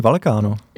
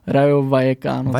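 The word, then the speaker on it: Rayo Vallecano: Uh, tak eh, já jsem tady, já tady mám zapsaný Valkáno. – Jo, já Fakt? taky, já taky. A dokonce jsem si doma uh, zjišťoval výslovnost a je to Rajo Vajekáno.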